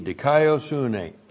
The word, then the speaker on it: dikaiosune: And the word, and all throughout Romans, this is the key word in the book of Romans, dikaiosune. D-I-K-A-I-O-S-U-N, long E, dikaiosune, righteousness.